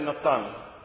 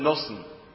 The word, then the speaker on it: Natan: And his name was Michael ben Natan. Michael ben Nosen in the Ashkenazi pronunciation.